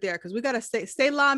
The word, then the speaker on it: Selah: Say la, yes. – You have the Jewish background, so I know I know how to say it now, because that's yes. – Selah.